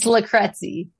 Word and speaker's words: Lucrezi: And why don't you pronounce your last name since I've butchered it so often? it's Lucrezi.